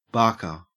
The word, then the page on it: barker: Australian English